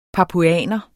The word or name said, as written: papuaner